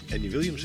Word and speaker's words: Andy Williams: Dat gaan we onder andere doen met Andy Williams, zeg ik dat goed?